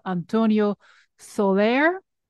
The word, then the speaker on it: Antonio Soler: Yeah, and then speaking of your scholarly work, I know you are deeply passionate about the music of Antonio Soler. Am I pronouncing okay? – Absolutely right.